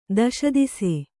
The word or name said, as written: ದಶದಿಸೆ